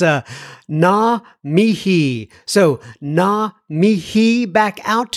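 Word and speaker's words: ngā mihi: It's NGAMIHI, and I think I've been pronouncing it Naga Mihi, but apparently the G is silent.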